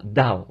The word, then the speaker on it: Daugh: Et à vrai dire, son nom, c'est D-A-U-G-H. Mais moi, je le prononce simplement Dow, vous voyez.